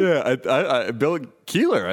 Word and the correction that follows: Kelliher: I thought it was. What'd you say, Kelliher? – Dude, it's spelled K-E-L-L-I-H-E-R. – Uh-huh. Oh, my bad.